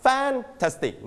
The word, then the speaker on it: fantastic: So where do you put the stress on? First, second, or third syllable? – Second. – So we pronounce fantastic. Not fantastic. No. Fantastic. Okay.